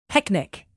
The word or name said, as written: picnic